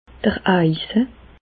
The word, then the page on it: d'r Eise: Alsatian; Bas-Rhin; Reichshoffen